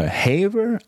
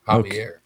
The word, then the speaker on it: Javier: The guy's name, I don't know if it's Javier or Haver. I don't know. – It looks like Javier. – Is that how you say it? You're looking at That's how you say it. Javier.